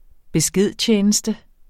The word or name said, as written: beskedtjeneste